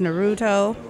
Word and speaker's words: Naruto: Buster and Amaya are into Demon Slayer, and I think they watched Naruto. Is that how you say it? – Yeah, you got yeah.